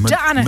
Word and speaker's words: Madonna: Madonna.